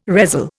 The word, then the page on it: resell: Indian